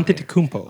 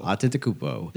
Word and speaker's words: Antetokounmpo: Antetokounmpo. – Antetokounmpo.